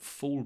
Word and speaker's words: fall: Often when I say fall, F-A-L-L, the L is not pronounced very much. Fall. He fell. I'm falling. When I say falling, however, it gets pronounced more. You can hear it more. Falling. This is a south of England thing.